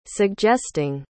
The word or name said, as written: suggesting